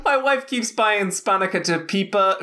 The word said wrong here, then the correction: spanakopita: Spanica Pipa, or whatever it's called. At how do you say the Greek thing? Spanakopita spanakopita.